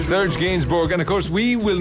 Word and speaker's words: Gainsbourg: So the song from your youth is this one by Serge Gainsbourg. – Oh, that's how you pronounce it, isn't it? – Yeah, Gainsbourg, yeah. – I would have said Gainsbourg, but it's Gainsbourg.